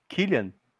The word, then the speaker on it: Cillian: É, que a pronúncia eu descobri que é Killian.